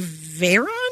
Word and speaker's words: Veyron: The most expensive tires in the world are $42,000 per set for a Bugatti Veyron? – Yeah, yeah.